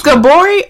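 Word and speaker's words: Gabourey: It's Gabori or Gabby? – It's Gabby for short, but her real name is, I believe, the proper pronunciation is Gabori.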